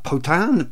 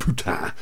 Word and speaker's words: Potin: And Moore's real name is Jerome, how do you say that, Potin? P-O-T-I-N, anyway, not Putin.